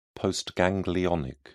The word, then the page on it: postganglionic: British English